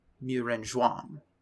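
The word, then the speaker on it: Muren Zhuang (木人桩): Muren Zhuang. More of a Zhuang. J sound.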